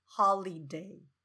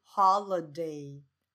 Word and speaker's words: holiday: 这个字可以注意一下哦，就不是 holiday，是 holiday holiday